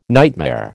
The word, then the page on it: nightmare: American English